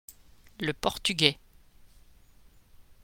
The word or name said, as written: le portugais